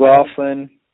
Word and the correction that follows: McLaurin: Yeah, Terry McLaurin, and I Oh, sorry. – believe, yeah, pronunciation's been pretty bad this spot.